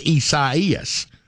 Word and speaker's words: Isaias: Should be pronounced Isaias, but they're saying it's Isaias.